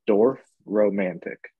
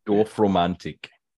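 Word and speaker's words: Dorfromantik: Have you heard of Dwarf Romantic? – Dwarf? Like D-W-A-R-F? – No, D-O-R-F. – Oh. – Atlantic is Dorf? Spelled with T-I-K at the end.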